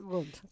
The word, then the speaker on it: Wundt: Wundt.